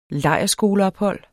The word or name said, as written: LEJRSKOLE